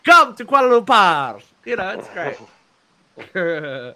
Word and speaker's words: Kuala Lumpur: Koala. – But, how do you say it? – Kuala Lumpur. You're so Australian.